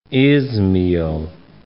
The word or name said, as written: Izmail